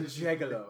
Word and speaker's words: gigolo: it's a soft J. – A gigolo.